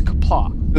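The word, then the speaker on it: Qapla': So, the first time that Michael Doran says, whoever says kapla, the first time, that's how you say pronounce those characters on the page. It's kapla.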